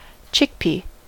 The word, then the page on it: chickpea: American English